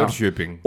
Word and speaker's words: Norrköping: Norrköping. – Of Hapoel. – Nee, Norrköping.